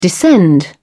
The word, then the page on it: descend: British English